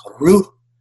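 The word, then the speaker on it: ruha (רוח): And it's pronounced in a way that sounds breathy. It's ruha. And it's intended to exhale, to give in the breathy sound.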